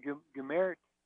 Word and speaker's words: Gohmert: I don't know how to say his name. Gohmert. I'm just looking it up. Apparently, you say his name Gohmert.